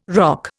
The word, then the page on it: roque: Indian